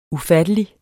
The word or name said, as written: ufattelig